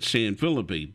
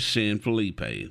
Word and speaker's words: San Felipe: Austin State Park is located in the northern part of the town, and there's a street in Houston named San Felipe, although longtime locals call it San Felipe, but the correct pronunciation is San Felipe.